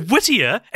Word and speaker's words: Whittier: So Whittier, it, it kind of rhymes with like Lydia, but it's not spelt like Yeah, that.